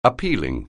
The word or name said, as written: appealing